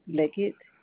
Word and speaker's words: Leggett: It's spelled J-E-W-E-L. And last name is Leggett, L-E-G-G-E-T-T. – Spell your last name again for me. – L-E-G-G-E-T-T.